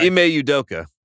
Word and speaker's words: Udoka: Ime Udoka.